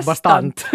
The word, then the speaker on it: bastant: jag märkte, du tittar på mig och första ordet som poppade upp var bastant. bastant. Tack. Bas med betoning på tant.